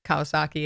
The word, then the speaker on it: Kawasaki: Kawasaki, yeah.